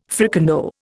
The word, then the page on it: fricandeau: Indian